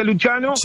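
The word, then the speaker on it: Lucciano: Luciano, lo escriben con doble C. Sí, se pronuncia Luciano como se pronuncia en italiano.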